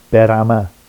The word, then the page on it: berama: Vafsi